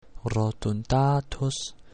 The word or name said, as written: rotundatus